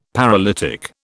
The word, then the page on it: paralytic: British English